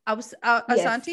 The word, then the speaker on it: Asante: Asante? Asante? Yes. Did I say it right? – Correct.